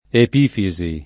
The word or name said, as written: epifisi